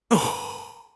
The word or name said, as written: 吼